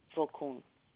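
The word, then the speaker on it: Vokoun: Mary Vocun. – And the last name is spelled V as in Victor, O-K-O-U-N? – Yes.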